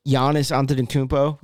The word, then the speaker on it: Giannis Antetokounmpo: You have Giannis Antetokounmpo. Yeah, great pronunciation. Nice.